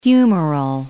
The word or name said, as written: humeral